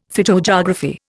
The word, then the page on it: phytogeography: Indian